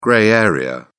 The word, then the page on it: grey area: British English